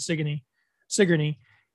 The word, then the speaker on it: Sigourney: And I was like, there's a town that's spelled like Sigourney Weaver's name and pronounced Sigourney.